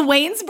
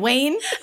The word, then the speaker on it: Wayans: Is it the Wayans brothers? Are you just saying Wayne? Like the first name? – It's a Michigan thing. – I cut down the syllables.